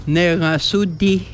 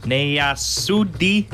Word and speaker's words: Nerasudi: How do you say you are funny? – Nerasudi. – Nerasudi. – Nerasudi. – Nerasudi. – Yes, right on. – Nerasudi. – That's it.